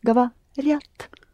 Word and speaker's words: говорят: Please don't insert the Y sound after R. Although, It's the letter ya, but there is a consonant in front of it. So you don't pronounce this ya. What ya does is making r soft. So you say gavariat.